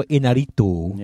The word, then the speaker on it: Iñárritu: Birdman, Alejandro Iñárritu.